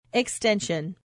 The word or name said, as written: extension